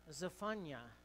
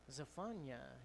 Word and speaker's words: Zephaniah: which to pronounce that is more Zephaniah, all right, so can you say that? Zephaniah, yeah, it's a little different.